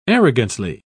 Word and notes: arrogantly: British English

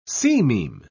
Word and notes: sememe: British English

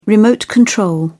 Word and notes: remote control: British English

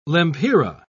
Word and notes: lempira: British English

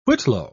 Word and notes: whitlow: British English

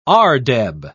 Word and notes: ardeb: British English

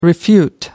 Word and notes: refute: American English

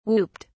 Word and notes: whooped: British English

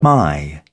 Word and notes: my: British English

